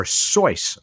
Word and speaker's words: Suess: Edwin King in the UK said that no astronomical feature has yet been named after the physicist Hans Seuss, or Seuss, apparently, but it's spelled S-U-E-S-S, though there are craters on the moon and Mars named after his grandfather, Edward, and an asteroid after his father, Franz Edward.